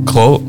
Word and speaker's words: grød: Yeah, actually reminds me, Rasmus says, I remember first encountering the Danish word for porridge, which is grød, no? – That's a really nice pronunciation. – Thank you. – Grød, yeah. – Grød. – You stick your tongue out.